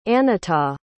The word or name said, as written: anetha